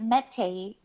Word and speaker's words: Matei: When you look at Matei, the word, the name, there's no accent on the second E.